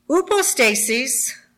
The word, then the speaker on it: hypostasis: So it's spelt like hypostasis, but we're not talking the medical word. We're talking the word that has to do with scripture. Upostasis means standing under an assumed position or what stands under, i.e.